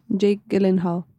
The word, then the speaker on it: Jake Gyllenhaal: Jake Gyllenhaal o cómo es. – Jake Gyllenhaal. – Ah, o sea, es lo mismo, pero, pero, como gringo.